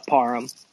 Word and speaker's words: Parham: Parham. – Parham. – Like, all right. I say it like this, like David Beckham. It's pronounced the same way. The hand at the end.